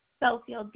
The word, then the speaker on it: Belfield: Belfield, B as in boy, EL, F as in Frank, ELD as in dog.